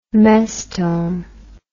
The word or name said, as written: mestome